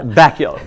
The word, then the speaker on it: Bakula: Scott Bakula. – And then Bakula rhymes with Dracula.